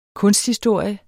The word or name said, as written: kunsthistorie